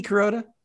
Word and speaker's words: Kuroda: They add Hideki Kuroda to the starting rotation. – What was that? – Yeah. Did I butcher that? – Hideki Kuroda? – There you go. There it is.